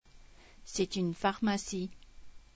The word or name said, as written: pharmacie